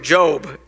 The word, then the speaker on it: Job: The story of Job in the Bible, now it's spelled J-O-B, so to us it looks like job. I can always tell people who are new in church, because I was reading this job thing. And it's not job, it's Job. It's how you say it.